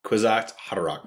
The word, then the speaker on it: Kwisatz Haderach: Kids watch Hodorach, right? – Something like that. – Come on. – Kwisatz Haderach. – There you go.